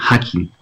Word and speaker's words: Hakim: Entonces, Hakim Morus, como suena, Hakim, como Yakin, como la entrada de Yakin y Boaz, pero se escribe igual.